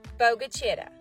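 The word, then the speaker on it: Bogue Chitto: Although it's 100% not spelled this way, it is pronounced Bogachitta.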